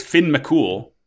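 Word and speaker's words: Fionn Mac Cumhaill: His name is Finn McCool, but is spelled Fionn Mac Cumhale, so that's something.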